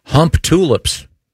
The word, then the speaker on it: Humptulips: In Grays Harbor County, Washington, there is a town called Hump Tulips.